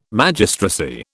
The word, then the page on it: Magistracy: British English